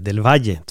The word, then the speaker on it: Del Valle: Typ som mitt dubbel L i mitt efternamn vill folk få till Del Valle eller Del Valle, men det är Del Valle, två L uttalas J.